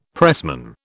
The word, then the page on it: pressman: British English